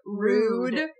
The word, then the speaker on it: Rood: So there's two twin advisors, and their names are Runnymede and Rude. – Yeah. – R-O-O-D. – Mm-hmm. Rude and Runnymede. Sorry, not Runnymede. I did mean Rude.